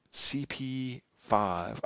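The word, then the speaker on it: CP-V: So CP minus V is pronounced CP 5 operating system.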